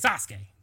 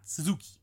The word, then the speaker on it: Sasuke: But his name is the great Sasuke, not Suzuki. But anyway, the U is silent.